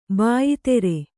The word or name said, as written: ಬಾಯ್ಧಾರೆ